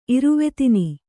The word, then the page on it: ಇರುವೆತಿನಿ: Kannada